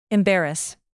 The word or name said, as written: embarrass